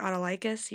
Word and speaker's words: Autolycus: I was going to say, I thought it was like Autolycus.